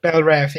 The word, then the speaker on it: Belle Reve: Belle Reve.